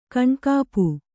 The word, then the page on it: ಕೈನೆಗಪು: kainegapu; Kannada